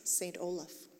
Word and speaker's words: St. Olaf: That's how you say it, right? – Well, there's no apostrophe, yes. St. Olaf.